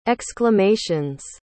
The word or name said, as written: exclamations